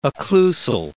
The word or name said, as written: occlusal